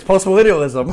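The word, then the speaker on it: postmillennialism: Or post-millennialism, I can never pronounce it. – It's post-millennialism.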